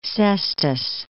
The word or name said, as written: Sestos